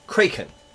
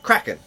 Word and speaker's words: Kraken: The word is pronounced Kraken, but when McNally, as Mr. Gibbs, first said the creature's name, he pronounced it Kraken, and this caught on with the rest of the cast.